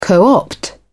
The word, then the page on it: co-opt: British English